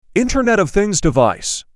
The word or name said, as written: Internet of Things device